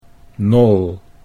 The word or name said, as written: Nol